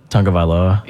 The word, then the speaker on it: Tagovailoa: Tagovailoa? – Yeah.